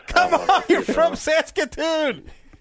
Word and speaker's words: Saskatoon: Kevin from Saskatoon, Saskatchewan. Is that being pronounced correctly? I'm a little off on my Canada stuff. – Perfect.